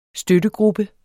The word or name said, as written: støttegruppe